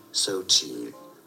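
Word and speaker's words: Xochitl: Aber wie spricht man diesen Namen einfach aus? Und jetzt? – Sochi. – Sochi. Nee, du hörst hinten noch ein L.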